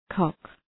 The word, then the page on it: cock: kɒk